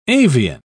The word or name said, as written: avian